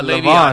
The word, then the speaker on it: Le'Veon: What about Le'Veon Bell? – Le'Veon Bell. – Le'Veon, I'm sorry. Right? I don't know Le'Veon. – Wow, I mispronounced it. I'm an idiot.